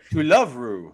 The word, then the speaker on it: To Love Ru: To Love Ru. – To Blank Love Ru. – I kept reading it in my head like, To Love Ru, Do love root to quit.